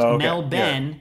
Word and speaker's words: Melbourne: It's pronounced Mel Ben. – Yeah. Okay. – Mel Ben, like my brother Ben. Mel Ben. Not Melbourne. With like a lot of O's. Melbourne.